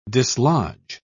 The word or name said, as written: dislodge